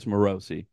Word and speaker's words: Morosi: Nick told me it's Morosi. That sounds like that's not it should be it feels like it should be Morrissey or something, but it's Morosi.